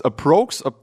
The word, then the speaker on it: Aprox: Aprox? Wie spricht man euch aus, Jungs? – Aprox. – Aprox. Aprox. Schönes ja.